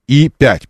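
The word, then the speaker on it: E5: В настоящее время на мощности калининградского автотора выпускают только бюджетный седан И-5. Пишется как Е, читается как И.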